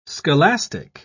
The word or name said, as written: scholastic